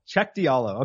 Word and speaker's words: Cheick Diallo: Cheick Diallo. Okay, so it is just like it looks. But former Kansas Jayhawk. – Is he? – Yes. – Okay. Cheick Diallo is a guy that I think that should be on your radar.